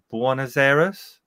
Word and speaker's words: Buenos Aires: So you've got paranormal researchers investigate strange events in a neighborhood in Buenos Aires.